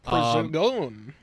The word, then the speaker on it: Prigozhin: Prigozhin.